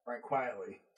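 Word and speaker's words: Frank Quitely: Mark Millar, Frank Quarterly. Quietly. Frank Quietly.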